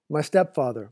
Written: my stepfather

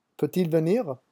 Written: peut-il

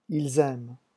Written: ils aiment

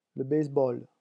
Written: le baseball

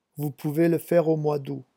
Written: vous pouvez

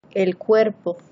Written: el cuerpo

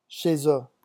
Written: chez eux

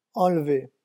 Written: enlever

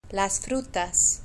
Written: las frutas

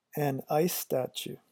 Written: a nice statue

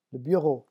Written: le bureau

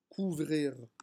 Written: couvrir

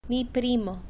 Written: mi primo

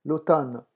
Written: l'automne

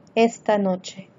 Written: esta noche

